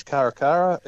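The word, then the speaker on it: Cara Cara: So what is the Caracara? Is that how it's pronounced? – Yes, Caracara.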